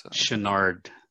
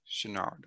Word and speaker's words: Chouinard: Chouinard. – Chouinard, okay.